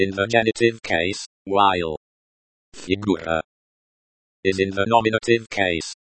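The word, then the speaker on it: Figura (фигура): Figura. Colon, the, u, sound is like the, u, in, moon.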